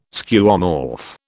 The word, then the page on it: Skeuomorph: British English